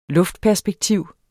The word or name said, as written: luftperspektiv